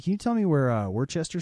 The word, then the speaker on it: Worcester: And he's like, oh, Worcester. – Worcester Street. And I'm like, no, no, no. There are many more letters in that word than you pronounced. – Worcester.